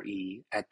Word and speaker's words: EEAT: However, Google has recently expanded on its EAT acronym to include another E at the very beginning. Still pronounced EAT, it is now EEAT.